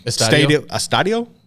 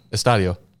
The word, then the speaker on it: Estadio: I probably butchered that. BBVA Estadio. – Estadio.